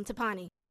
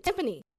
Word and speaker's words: timpani: Say it right. – Tapani? – Nope. – Spell it. I think I know which one she's talking about. – I know exactly what she's talking about, but she's saying it wrong. – What is it? Timpani.